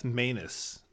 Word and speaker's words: Maness: Maness is how it's pronounced. – I did have to look that up because I've heard it both like multiple ways, and Maness versus Maness. Yep. So it's pronounced Maness like a.